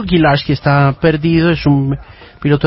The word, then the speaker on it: Gilarski: Told Gilarski, pilot missing in Colombia, dice el titular.